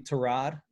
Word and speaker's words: Tyrod: Oh, on HBO, Hard Knocks, that you actually pronounce his name Tyrod? – It is, yeah.